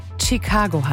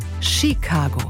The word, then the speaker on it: Chicago: Und bis heute hatte ich ehrlich gesagt keine Komplexe, diese Stadt auszusprechen, bis hier ein Klugscheißer-Redakteur anmerkte und uns alle darauf hinwies, dass es nicht Chicago heißt, sondern Chicago. Ein kleiner, aber feiner Unterschied. Also nicht mit einem T vorne ausgesprochen, Chicago, sondern Chicago.